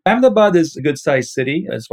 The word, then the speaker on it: Ahmedabad: And the city is Ahmedabad? – Ahmedabad. Yeah.